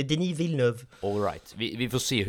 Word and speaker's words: Villeneuve: Villeneuve. Villeneuve.